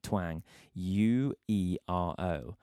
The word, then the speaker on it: Twanguero: and that is spelled T-W-A-N-G, like twang, U-E-R-O, El Twanguero.